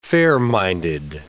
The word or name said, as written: fair-minded